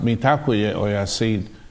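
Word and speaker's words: Mitakuye Oyasin: Kind of reminds me of a Lakota saying that we have, pronounced Mitakuye Oyasid, and what it means is that we're all related.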